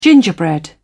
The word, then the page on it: gingerbread: British English